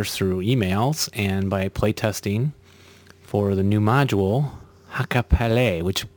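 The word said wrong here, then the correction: Hakkaa Päällä: Uh, I would pronounce it Hakapelle. If you speak with someone from, from Finland, they would say Hakapelle, I think.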